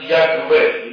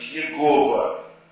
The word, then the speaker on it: Яхве: Предположительно, оно произносится как Яхве или Яхве. Но это только предположительно. В древности его читали как Иегова. Что неверно.